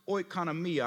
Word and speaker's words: oikonomia: It says, according to the stewardship from God, where this word stewardship would have been pronounced oikonomia.